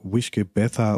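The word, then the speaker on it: uisge beatha: Wishke Betha oder sowas wird das, glaube ich, ausgesprochen und heißt eben Wasser des Lebens.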